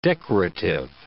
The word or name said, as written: decorative